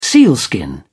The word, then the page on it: sealskin: British English